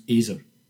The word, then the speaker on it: Ezer: And in biblical Hebrew, the phrase for a suitable helper or helper fit for him is Ezer Kenegdo, pronounced Ezer. E-Z-E-R, Ezer.